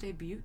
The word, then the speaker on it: debut: Is it actually pronounced debut? – Did I say debut? – Yes. – Oh, I didn't hit the T.